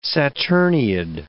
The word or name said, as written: saturniid